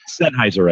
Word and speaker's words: Sennheiser: And Sennheiser, I can never pronounce that name. But is it Sennheiser? Yeah, that microphone. – Good enough for us. – Yeah. – Sennheiser, I think is what it is. – Yes, that's it.